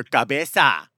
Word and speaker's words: cabeza: You can choose what you put into your cabeza. Is that how you say it? – Yeah, your cabeza.